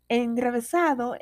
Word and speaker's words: enrevesado: Enrevesado es una palabra llana, no se acentúa en la penúltima sílaba debido a que ésta termina en vocal.